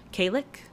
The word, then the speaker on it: Khalic: But I think it's Kalic. It's spelled K-H-A-L-I-C. Kalic.